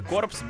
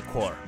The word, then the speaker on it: Corps: Again, it's spelled C-O-R-P-S. And I have no idea why they're wasting 40% of those letters. P.S., according to the band, is not pronounced. I would say it should be pronounced The Corpse, but it's pronounced The Core.